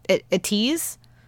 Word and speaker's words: ATEEZ: but I felt like a tease. Is that how you say it? – Yeah.